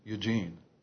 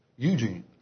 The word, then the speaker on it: Eugene: If you're from the north, you'll say Eugene, like Eugene, Oregon. But in the south, it's pronounced Eugene.